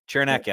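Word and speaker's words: Cernak: I think it's Cerniak. Does that make sense? – Cerniak. – We were just Cerniak. Chernak, yeah, I'd look at.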